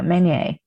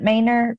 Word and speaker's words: Manier: It's M-A-N-I-E-R. My family was hella country and they pronounced it Maynard, but it's French. Okay. And you said it right. But if you are hella country too, you could say Maynard. It's all right. It ain't gonna offend me or anything.